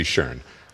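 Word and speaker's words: Schön: And they are made by Boston's favorite sculptor, Nancy Schoen. It's S-C-H-O-N with an umlaut, so I'm sorry if I'm mispronouncing it. – You did a good job there.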